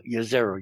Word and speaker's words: Jezero: Jezero, you pronounce it like with a Y, we have been told, which used to be an ancient lake.